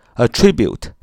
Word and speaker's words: attribute: attribute 重音在 tribute，tribute 哪啊？attribute，attribute，a t t r i b u t e，a t t r i b u t e，a t t r i b u t e，attribute，attribute，attribute，及物动词把什么什么归于。